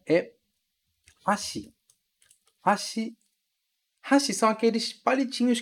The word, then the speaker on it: hashi: E esse X do final, que é escrito S-H-I, ok? Hashi. Aqui no Brasil, a gente costuma falar hashi para chamar esses palitinhos, mas a pronúncia mesmo em japonês é hashi, hashi, com a ênfase lá no ha e não no shi, certo?